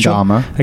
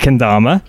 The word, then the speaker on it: Kendama: Sean was playing, I can't even pronounce this, what is Kendama. – It Kendama.